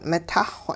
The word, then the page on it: mitahxw: hupa